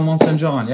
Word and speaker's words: Monsaingeon: That's right. Axel Monsaingeon. Yeah, it's French.